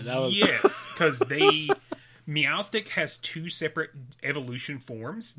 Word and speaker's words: Meowstic: Meowstic? – Yes.